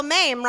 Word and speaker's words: Mamrie: Like, no one ever I go, Mame rhymes with name, and they're always like, you got it, Moo-moo.